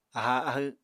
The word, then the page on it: aháa ahw: Apatani